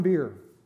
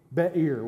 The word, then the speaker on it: Be'er: Be'er would be the way to say it properly, but it's more fun to say Beer.